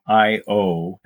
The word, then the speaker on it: i/o: Another 70s, 80s artist that had a new album came out near the end of the year is Peter Gabriel, his album I.O. I think that's how you pronounce it. – Yeah, it's like I/O.